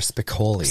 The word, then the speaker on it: Spicoli: Are you saying Spagoli or Spicoli? – Spicoli. – Thank you. – Oh, I was going G, wasn't I? Spicoli. – It was a hard G.